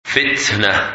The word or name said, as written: Fitnah